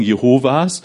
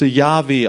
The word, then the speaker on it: Yahweh: Deshalb haben wir das Problem mit den Zeugen Jehovas, weil man dachte, es wird Jehova ausgesprochen. Erst durch Sprachforschung im letzten Jahrhundert haben wir herausfinden können, nee. Das müsste Yahweh ausgesprochen werden.